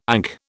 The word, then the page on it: ankh: British English